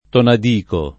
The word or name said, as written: Tonadico